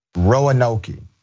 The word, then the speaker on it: Roanoke: By the way, for audio only viewers, the pronunciation is Roanoke.